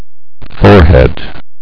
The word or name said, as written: forehead